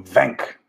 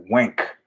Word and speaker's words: Wenk: When I went to Vegas to have a meeting with those people, when I was representing Future Publishing, met with Jennifer uh, Wenk, uh, Venk. I keep wanting to pronounce it the German way, Wenk.